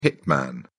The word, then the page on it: hitman: British English